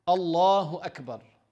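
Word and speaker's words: Allahu Akbar (الله أكبر): Even you see some people like writing on the wall, they want to write Allahu Akbar in Arabic, they add the waw in between. Ignorant. Allahu Akbar. This is how you say it.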